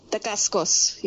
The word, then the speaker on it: Dacascos: And this was directed by Mark Dacascos, if I'm pronouncing that right. I don't care. – Dacascos, yeah.